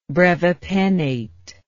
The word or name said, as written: brevipennate